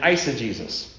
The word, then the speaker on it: eisegesis: There's exegesis and eisegesis. Okay? It has nothing to do, it sounds like Jesus, but it's not. It's the word, how you say it.